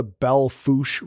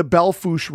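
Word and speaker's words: Belle Fourche: And the river that's there right now, it's called the Belle Fouche River. And the Fouche part of it is spelled F-O-U-R-C-H-E. So it's probably the most mispronounced word that people are reading the interpretive signs.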